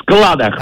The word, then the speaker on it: складах: Ты смотри, совершенно верно, согласно орфоэпической норме русского языка, в данном слове во всех падежных формах, в том числе и во множественном числе, ударение остается неизменным и падает на гласную букву А в корне, поэтому правильно говорить еще раз как? – В складах.